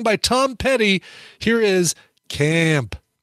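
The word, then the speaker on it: Caamp: I say it like that because it's got two A's. Camp. – Oh, weird. – If it was one A, I'd say Camp, but it's two A's, so I say Camp. The name of the song is Square One.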